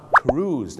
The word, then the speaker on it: cruise: คำว่า cruise นั่นเองครับถูกต้องครับ cruise เหมือนทอมครูซ